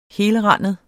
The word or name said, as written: helrandet